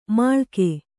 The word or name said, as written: ಮುಳುಗು